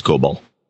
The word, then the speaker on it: COBOL: That's it's COBOL. It's pronounced COBOL. He got the pronunciation right.